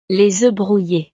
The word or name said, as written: les oeufs brouilles